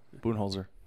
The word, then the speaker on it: Budenholzer: Coach of the Year was Mike Budenholzer. – Yep. – Is that how you say it? – Yeah. Budenholzer.